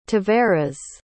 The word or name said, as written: tavares